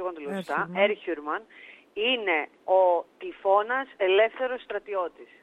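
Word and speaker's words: Erhürman: Ναι, είναι Ερχιούρμαν τελικά στο, ε. – Μπράβο, είναι, ναι, γιατί Ερ... Ναι. Ερ σημαίνει στρατιώτης, χιούρ σημαίνει ελεύθερος και κατάληξη μαν. Και όταν είναι τρεις συλλαβές, τονίζεται πάντα στην πρώτη.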